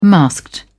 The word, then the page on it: masked: British English